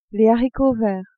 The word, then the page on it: haricots verts: female